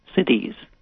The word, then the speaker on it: siddhi: So telepathy is considered one of the siddhis. That's a Sanskrit term. It's spelled S-I-D-D-H-I.